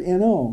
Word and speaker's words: know: Sounds the same as the word N-O, no. We're talking about K-N-O-W, of course. In the preterite tense, it's new. Past participle, it's known, K-N-O-W-N.